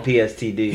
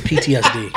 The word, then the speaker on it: PTSD: PTSD. – Booyaka, booyaka, calm down, selector. – Yeah, so it's PTSD.